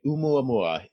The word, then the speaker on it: ʻOumuamua: and that's ʻOumuamua. Am I saying that right? – You are saying it right. ʻOumuamua.